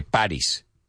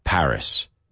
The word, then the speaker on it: Paris: I always hear people say Paris, Paris, right? In English, it's mucho más suave. It's Paris, Paris. Say it with me. Have you ever been to Paris?